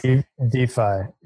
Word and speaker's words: DeFi: One thing that, this idea of banking, is it DeFi or DeFi? I don't know how it's pronounced. – DeFi. Yeah. DeFi. It's a shortening of decentralized finance. And it has the added benefit of sounding like DeFi, defiance, DeFi. I love it.